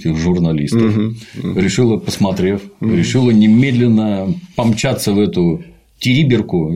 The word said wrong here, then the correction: Териберка: Я все время ударение неправильно ставлю. Териберка, она, по-моему, посмотреть, так ли там все на самом деле.